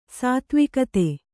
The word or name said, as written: ಸಾತ್ವಿಕತೆ